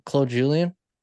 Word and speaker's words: Claude Julien: Or, yeah, am I saying that right? That sounds weird for some reason. Claude Julien? Yeah, that's Claude right. Julien, yeah.